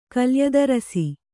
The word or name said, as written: ಕಲ್ಯದರಸಿ